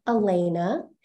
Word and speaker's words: Elena: or it's pronounced Elena, not Alana. Or I kindly say Elena, and now I'm proud to say my full name with correct spelling, Elena Bailey Thomas.